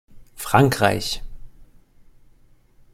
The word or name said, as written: frankreich